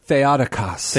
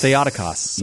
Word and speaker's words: Theotokos: How do you pronounce it? Theotokos? – Theotokos. – Theotokos. Yes. Also called Theotokos by the less educated.